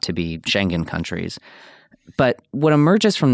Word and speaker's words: Schengen: Schengen countries. I'm not sure if I'm pronouncing that correctly. – That's absolutely correct. Yeah, Schengen.